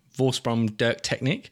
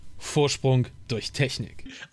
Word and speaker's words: Vorsprung durch Technik: Audi, Vorsprung durch Technik.